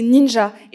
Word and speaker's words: Ninja: Tu l'as vu, qu'est-ce que tu en as pensé alors, premier truc, le vrai, la vraie prononciation du nom de la réalisatrice, c'est Ninja.